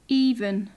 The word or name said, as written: even